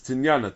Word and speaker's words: tinyana: That it's not tanina. It's tinyana. Tinyana is the second one.